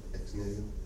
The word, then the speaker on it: ex nihilo: Ex nihilo. – What's that? – Ex nihilo. Like, yeah. How do you say it again? – Ex nihilo.